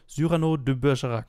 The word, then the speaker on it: Cyrano de Bergerac: Cyrano de Bergerac.